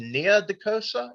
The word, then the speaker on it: Nia DaCosta: I think her name first name is Nia DaCosta. Yeah, am I saying that right? – Sounds right.